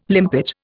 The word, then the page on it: limpet: Indian